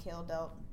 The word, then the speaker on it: Kjeldahl: His last name is spelled K-J-E-L-D-A-H-L. That I had to look up that it was pronounced Kjeldelt because...